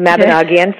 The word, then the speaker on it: Mabinogion: So I think if you were to look at, like I looked at historical, you know, stories, the Mabinogion, I didn't say it wrong, the Mabinogion, for example, the Welsh and Celtic traditions.